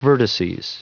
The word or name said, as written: vertices